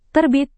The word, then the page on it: terbit: indonesian